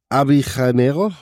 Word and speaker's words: Avellaneda: Und Independiente und Racing Club Buenos Aires sind im selben Stadtteil in Buenos Aires, in Avellaneda. Ich hoffe, ich habe es richtig ausgesprochen. – Sí, absolutamente.